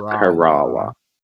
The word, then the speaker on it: Corolla: There's a place up north, past Corolla, and yes, it's pronounced Corolla. It's spelled like Corolla. A Corolla. – But it's pronounced Kerala.